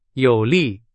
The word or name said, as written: 有利